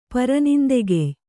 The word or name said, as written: ಪರನಿಂದೆಗೆಯ್